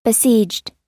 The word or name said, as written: besieged